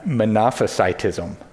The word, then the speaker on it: monophysitism: Fifth, and the hardest to pronounce, kind of pronounce it like monopoly, the beginning of that. It's monophysitism. Monophysitism.